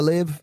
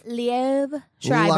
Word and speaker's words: Liev Schreiber: Did I say that right? – I think you did. Liev or Liv? – Liev Schreiber.